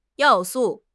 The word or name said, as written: 要素